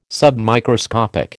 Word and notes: submicroscopic: American English

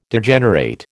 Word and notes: Degenerate: American English

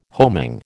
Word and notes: homing: American English

